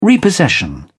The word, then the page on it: repossession: British English